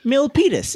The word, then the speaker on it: Milpitas: For listeners not from around here, Milpitas is how you pronounce that.